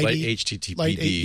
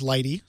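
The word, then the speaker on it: lighttpd: I'm not sure exactly how you say it. Lighty. – Light HTTPD. – Yeah, exactly. – It's a web server. – Yeah, commonly known as Lighty.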